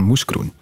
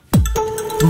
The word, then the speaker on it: Mouscron: En dat heeft voor ophef gezorgd, vooral dan in het geval van moeskroen. Mouscron